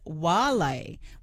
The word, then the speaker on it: Vale: And Anne from Canamble has said that the correct classical Latin pronunciation of Vale is Wale with a W.